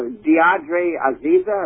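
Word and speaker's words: de'Adre Aziza: I can't pronounce her right. You know, Deirdre Azeza. Am I saying that right? – Uh huh.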